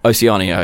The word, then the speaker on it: Oceania: Oceania, okay.